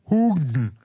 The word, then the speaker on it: Haugen: Francis Hugen. Haugen geschrieben. Haugen. Francis Haugen. Aber sie spricht sich selbst auch Haugen aus.